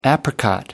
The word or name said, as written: apricot